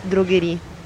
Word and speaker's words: droguerie: Vous lisez droguerie. – Droguerie. Droguerie. – Droguerie. Donc la E, vous ne la lisez pas. – Droguerie. – Droguerie. – On ne prononce pas, c'est marqué, mais on ne prononce pas.